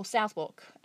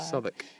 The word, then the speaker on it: Southwark: Or Southwark, as I probably pronounced it incorrectly. – Jeez. Yeah, when we first moved here, yeah. – Soon corrected by... – Southwark, The Union Theatre has a bit of a, like, Brisbane arts charm about it, doesn't it? – Yeah, anybody from Brizzy?